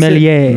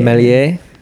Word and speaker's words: Méliès: No, how do you pronounce Méliès. – It? Méliès.